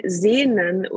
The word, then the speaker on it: Sehn: So you spell it S-E-H-N, Sehn, and then S-U-C-H-T.